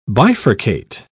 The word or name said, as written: bifurcate